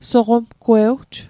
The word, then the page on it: soqomgwewj: Mi'gmaq